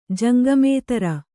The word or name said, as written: ಜಂಗಮೇತರ